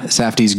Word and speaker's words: Safdie: That one is a priority, but, I did see, Ben and Joshua Safdie's, is that how you say it? – Yeah. – Safdie's Good Time.